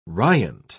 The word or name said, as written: riant